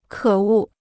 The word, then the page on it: 可恶: kě wù